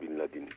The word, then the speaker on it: Bin Laden: Evet, bu bunun çok somut bir örneği Bin Laden'in veya Ben Laden, nasıl artık diyeceğiz, tam bilmiyorum Arapça telaffuzuyla Bin Laden olması lazım.